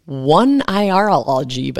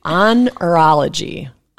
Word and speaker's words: oneirology: It's spelled like oneirology, but that's not how you pronounce it. It's onirology. Onirology, okay. The study of dreams.